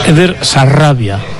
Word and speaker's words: Eder Sarabia: A ver, pronuncia bien, Raúl. Es Eder Sarabia.